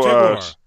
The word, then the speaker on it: Che Guevara: Okay, you said Guarvo. – Okay, my bad. – Okay, cool. – So, Che Guevara.